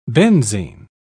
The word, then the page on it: benzine: British English